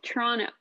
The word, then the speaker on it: Toronto: And I'm like, Toronto. And anyways, apparently it's pronounced Toronto.